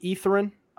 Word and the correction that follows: Etherton: I'm not sure. – I think it's Etherton. – Is it Etherton? All right. Looks Brady good. Etherton. My bad for the pronunciation.